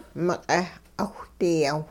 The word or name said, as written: miq'eh 'awhdiyawh